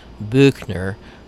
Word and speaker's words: Buechner: His last name is spelled B-U-E-C-H-N-E-R. Most people think it's Buechner with an umlaut, but it's not. It's Buechner.